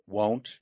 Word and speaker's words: won't: It's a long O, right? Won't.